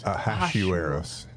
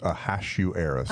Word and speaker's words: Ahasuerus: Ahasuerus. – Yeah. – It's Hash. – Hash? – Not Hosh. Oh I looked gosh. It up. I have a book that shows me how to do this. Ahasuerus.